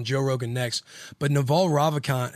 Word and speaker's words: Ravikant: Yes. Say that. – Ravikant? – Ravikant. – That's how I'd say it.